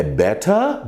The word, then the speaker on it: better: Melhor é better, better no britânico e better, better, better no americano.